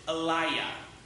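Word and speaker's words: Eliyah: The Hebrews would pronounce it Eliah. So Eli, meaning "my God," and Yah would be short for Yahweh.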